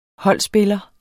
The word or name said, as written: holdspiller